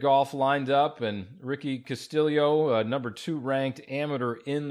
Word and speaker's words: Castillo: It looks like Castillo, but he has told me that it's pronounced Castillo, almost like there's an I between the L and the O.